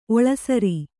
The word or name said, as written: ಒಳಸಾರ